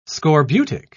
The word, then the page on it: scorbutic: British English